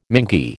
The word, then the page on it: mingy: American English